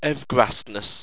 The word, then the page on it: evgrassness: Owstwecian